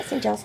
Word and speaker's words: St. Giles: I mean, he learns from Susan that the Fellowship of St. Giles... Is that saying that right? – Yep, yeah, St. Giles.